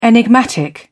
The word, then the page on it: enigmatic: British English